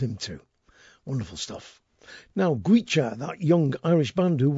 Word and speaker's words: Goitse: Gwecha is spelt G-O-I-T-S-E.